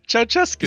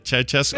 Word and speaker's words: Ceaușescu: Ceaușescu. – Is that how you say it? Ceaușescu.